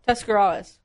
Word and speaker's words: Tuscarawas: It's a light word. Tuscarawas. – Okay. – Just flows.